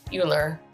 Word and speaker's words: Uhler: So today, what we're going to do is we're going to talk to John Uhler.